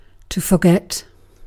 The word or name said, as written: forget